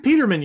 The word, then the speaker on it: Peterman: You can't tell me that, like, Nathan Peterman, or I'm saying his last name wrong, the guy that played for the Bills that was terrible. – No, Nathan Peterman, you're right.